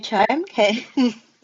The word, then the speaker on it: Charmkins: Am I saying it correctly? – I believe you are. They are Charmkins.